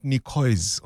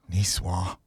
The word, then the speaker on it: Niçoise: First time I thought, oh, is that how you say it? Not Nicoise or Niçoise. Niçoise.